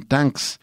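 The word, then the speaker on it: Tanx: Tanks, se escribe T-A-N-X y podría significar tanques como parece sugerir la portada de él con un pequeño tanquecito de juguete o también una pronunciación curiosa de gracias de Tanks Y eh,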